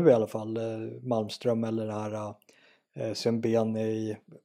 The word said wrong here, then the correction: Sabuni: jag kan inte uttala efternamn, vad heter hon? – Uh, Saboni heter ja, hon va? Sabuni, ja, ja precis.